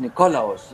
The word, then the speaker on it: Nikolaos: Agios Nikolaus. Agios ah, Nikolaos, Nikolaos. auf dem O bitte, betont. Nikolaos.